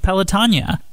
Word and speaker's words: Pelotonia: I'm Features Editor at the Dispatch, and today I'm here with Joe Apgar, Chief Operating Officer of Pelotonia. That's right, that's how it's pronounced, not Pelotonia.